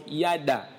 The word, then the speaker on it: yada: Le mot connu ici, connaître, c'est un mot hébreu que l'on prononce yada, Y-A-D-A, qui veut dire connaître intimement.